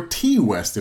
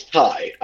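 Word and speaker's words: Ti: And it was directed by Ty West, or T West, if that's how you're pronouncing it, because it's spelled T I. – I feel like it's Ty. – It is Ty West.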